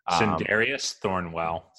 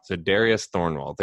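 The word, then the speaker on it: Sindarius Thornwell: I don't know how to pronounce his name just yet. – Za'Darius Thornwell. – Za'Darius Thornwell. There you go.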